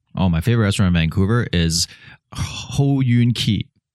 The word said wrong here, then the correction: He Yuan Ji: I know I can't pronounce it. It's He Yuan Ji. – Okay. – He Yuan Ji.